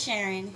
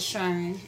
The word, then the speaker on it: Sharon: My name is Sharon. No one pronounces it right, so it's Sharon, S-H-U-R-N.